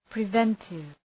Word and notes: preventive: prı’ventıv

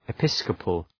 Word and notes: episcopal: ı’pıskəpəl

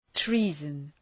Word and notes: treason: ‘tri:zən

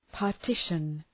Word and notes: partition: {pɑ:r’tıʃən}

